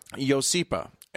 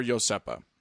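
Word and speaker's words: Iosepa: Yosipa and or Yosepa. – Yes. – Both are acceptable pronunciations.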